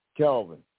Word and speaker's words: Kelvin: It's Kelvin. – Kelvin? Kelvin? – Yeah, Kelvin. – Spell that for me. – K-E-L-V-I-N.